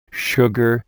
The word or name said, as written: sugar